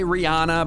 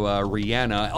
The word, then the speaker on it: Rihanna: I say it the way she says it. I used to say Rihanna, but then I figured she knows best, right? So Rihanna.